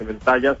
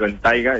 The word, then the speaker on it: Bentayga: No se dice ventaja, se dice ventaiga, está bien dicho.